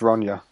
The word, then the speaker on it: Ronja: Which is why it's pronounced Ronja as well.